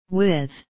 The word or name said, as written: with